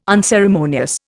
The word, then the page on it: unceremonious: Indian